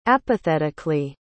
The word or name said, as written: apathetically